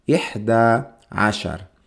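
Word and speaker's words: إحدى عشر: Y se dice ihda ashar. Ashar. Ihda ashar.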